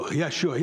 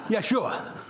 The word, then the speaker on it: Yeshua: You know, now if you're from New York, you have no problem pronouncing that Hebrew name. Because when you answer a question in the affirmative from New York, you say, Yeshua. Yeshua.